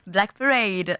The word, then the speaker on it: Black Parade: Alors, avant de vous saluer, comment ça se prononce ? – Black Parade. – Ouais, ça va. – C'était très bien.